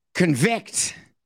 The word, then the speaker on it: convict: Convict. It makes such a difference at where you put the syllable and the emphasis.